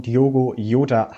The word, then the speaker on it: Diogo Jota: Genau, also erstmal, du hast ihn richtig ausgesprochen, Diogo Jota heißt er,